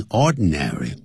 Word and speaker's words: ordinary: 本来这个要念成 ordinary ordinary 但是那个 den 念起来比较的外国人呢他就懒惰就念 ordinary ordinary ordin 用鼻音就像